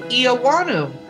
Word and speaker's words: Eoannou: And our guest today is Steve Eoannou.